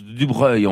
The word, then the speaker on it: de Broglie: Alors, en fait, quand on prend le nom qui s'écrit de Broglie, et ce qui se prononce du Breuil, en fait, c'est voisin de ça.